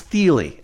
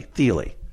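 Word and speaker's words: Thile: an incredible guy whose name I finally just learned how to pronounce. It's Thiele, everybody, Thiele.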